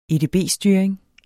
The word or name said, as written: edb-styring